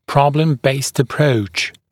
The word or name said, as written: problem-based approach